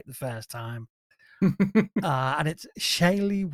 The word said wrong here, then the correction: Shailene: Woodley. Right. She was in the Divergent films. – See how Siri wants to say it. – Shailene Woodley. – Shailene.